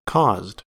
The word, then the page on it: caused: American English